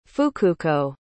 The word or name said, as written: fukuko